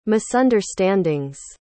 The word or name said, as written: misunderstandings